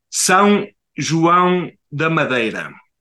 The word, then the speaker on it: São João da Madeira: And if I can pronounce the name of the city right, say Zoa de Madeira. How am I very close? Yeah, almost. It says São João da Madeira.